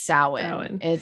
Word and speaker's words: Samhain: They call them by their it's not Halloween, it's Samhain.